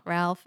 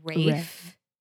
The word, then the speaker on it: Ralph: This is very hard because his name is not Ralph. It's Rafe.